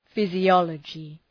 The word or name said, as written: physiology